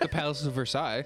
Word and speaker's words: Versailles: Yeah, it's pronounced Versailles.